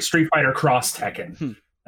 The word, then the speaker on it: Street Fighter X Tekken: Street Fighter Cross Tekken. X is actually pronounced Cross.